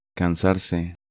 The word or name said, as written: Cansarse